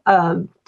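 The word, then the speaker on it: Koch: And I've recently taken a deep dive into multiple intelligences, also known as the 8 Great Smarts, Kathy, K-O-C-H. I still don't know how to pronounce her name. – Yes. Uh-huh. – Cook, has written a book on that.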